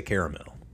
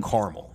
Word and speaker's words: Carmel: Me personally, being from the South, I say caramel. – But I think we have to defer to the residents. – Exactly. So Carmel. – Carmel. – Is how they pronounce it. – That's how they all pronounce it. They don't say caramel. – So I will say Carmel.